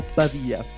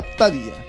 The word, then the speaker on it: Pavia: Vediamo di inquadrare un attimo Pavia. Pavia in dialetto pavese si pronuncia Pavia.